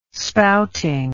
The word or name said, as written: spouting